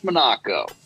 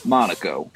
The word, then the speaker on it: Monaco: What fabrics were available for the seats in the 1975 Dodge Monaco? I mean, Monaco.